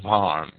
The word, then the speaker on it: Worms: Some sources state that on this day in 1521, Luther appeared before the Diet of Worms, or Worms, as I believe it's pronounced in German.